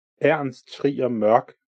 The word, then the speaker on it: Ernst Trier Mørk: I'm gonna do is when I come back to edit this, I am now gonna edit in saying it properly. So Ernst Trier Mørk, Bjørn O. Ibsen. – Okay, so that's how it should be said.